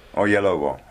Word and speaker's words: Oyelowo: David Oyelowo, right? Where's the accent? – Yes. – Where's the accent? On the second syllable? – Oyelowo.